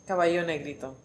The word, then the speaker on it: Caballo Negrito: So we just had to grab another beer, and this one is Caballo Negrito. – Okay. – Right? Mm-hmm. Okay, I said it right. And I'm like, I know Spanish. I should know how to pronounce it.